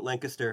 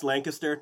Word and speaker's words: Lancaster: I've been to Lancaster, and it's pronounced Lancaster, not like Lancaster.